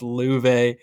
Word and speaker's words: Love: First name spelled Love, pronounced Luve. I love it. I love Luve. So, played in Sweden last year, 10-9, 3.31 goals against, 8.95 save percentage.